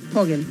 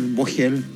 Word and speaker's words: Vogel: Fogel. – Fogel. Bueno, sí, está bien, pero puede ser Fogel, Vogel. Me han dicho de mil maneras. En alemán es Fogel.